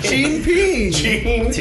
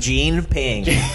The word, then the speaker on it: Xi Jinping: It's Xi Jinping. – Jinping. – There it is. Xi Jinping. That's their his or her name. Because Xi Jinping. – It's J-E-A-N-N-E.